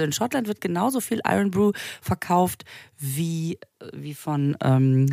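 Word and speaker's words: Irn-Bru: Es schreibt sich Irnbru, mhm. – weswegen wir ja auch die ganze Zeit von Irnbru gesprochen haben. Es wird mhm. aber ausgesprochen Iron Brew, was so viel heißt wie Eisengebräu.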